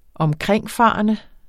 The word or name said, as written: omkringfarende